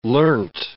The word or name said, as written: learnt